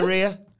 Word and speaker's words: Rhea: Would you Nia Rhea? – I would. – Yeah. – It rhymes with diarrhea now you said it, so I don't like it anymore.